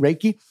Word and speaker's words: Reiki: I actually, dude, I didn't believe in Reiki. Is that how it's pronounced? Mm-hmm. Reiki.